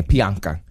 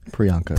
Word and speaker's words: Priyanka: Priyanka. – Priyanka. – Priyanka.